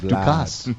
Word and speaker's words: Vlad Ducasse: Yeah, say it right. Ducasse. – Ducasse. – Not Ducasse. Vlad. Ducasse. – Vlad. – That's how you say it. Vlad Ducasse.